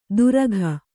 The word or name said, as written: ತುರಕ